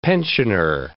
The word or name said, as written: pensioner